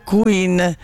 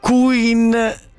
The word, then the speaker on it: Quinn: L'autrice si chiama Meghan Quinn, si pronuncia più o meno Meghan Quinn, non Queen all'italiano.